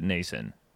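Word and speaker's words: Noesen: How is it spelled? N O E S. – Yeah. – Yeah, I think I've heard them pronounce it Nason. – Yeah, I think you're right.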